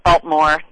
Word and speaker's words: Baltimore: So, of course, you got to say Baltimore, drop the extra syllable in there.